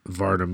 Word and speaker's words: Varda: And this is my first Varda movie. I think we're saying that right, by the way. – Yeah, I believe that's how it's pronounced.